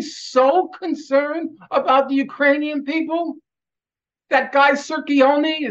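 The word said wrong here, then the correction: Cirincione: is that how you pronounce his name? – Joe Sirincione, yeah.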